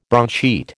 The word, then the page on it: broadsheet: American English